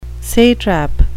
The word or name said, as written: satrap